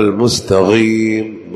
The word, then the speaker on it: المستقيم: المستقيم بالقاف من أقصى الحلق، ما يقول المستقيم، مستقيم ما في شيء اسمه مستقيم في اللغة العربية.